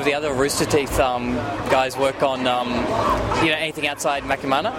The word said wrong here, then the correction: Machinima: How do you say Makimana? That's Machinima.